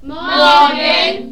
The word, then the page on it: morgen: german